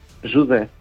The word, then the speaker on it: José: Pero José en portugués, José. – Jude. – ¿Cómo? – Jude.